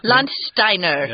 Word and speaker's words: Landsteiner: The biggest advance in this area of medical science came in 1901 when Austrian researcher Karl Landsteiner, or maybe Landsteiner? – Landsteiner. – That's probably how you say it auf Deutsch.